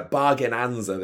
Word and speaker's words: Bargainanza: But you didn't know, you didn't say it right. – I thought it was just Bargainza. I was really thinking about like Sheldon off the Big Bang Theory with his Bazinga. But yeah, Bargainanza, this is called.